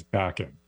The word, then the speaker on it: Bakan: Well, there are different ways people pronounce it. Bakan, Bakan. But the correct pronunciation is Bakan.